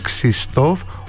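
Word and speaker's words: Krzysztof: Αυτός είναι προσπαθεί 7 χρόνια να μάθει και μας λέει ότι ο Βαζέχα στα πολωνικά η ακριβής προφορά του ονόματος είναι Ξιστόφ Ουαρτζίχα, αλλά το γου ελάχιστα ακούγεται.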